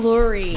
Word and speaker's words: Lurie: L-O-A. My last name's Lurie, actually. Lurie. – It's not lore. The new Lurie.